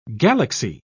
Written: galaxy

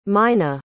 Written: meine

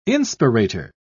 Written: inspirator

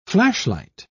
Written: flashlight

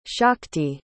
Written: shakti

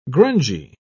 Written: grungy

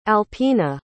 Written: alpina

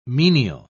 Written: menial

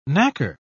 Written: knacker

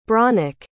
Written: brónach

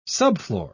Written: subfloor

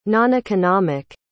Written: noneconomic

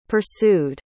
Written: pursued

